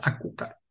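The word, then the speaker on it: açúcar: Es azúcar, pero se pronuncia acucar Ahí está el amigo Ray Castillo en Lisboa, Portugal.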